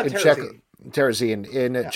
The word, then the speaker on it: Terezin: Yeah, Terezin.